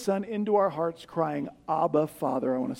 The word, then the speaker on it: Abba: You pronounce it Abba, so we spell it A-B-B-A.